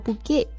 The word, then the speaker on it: bouquet: 嗯，这个 bouquet 就对应的是花束的意思，比方说一束玫瑰就是 a bouquet of roses。 – 嗯，不过要注意啊，因为 bouquet 它其实是来自于法语，在法语里呢就读作 bouquet，所以它末尾的这个字母 t 是不发音的哈。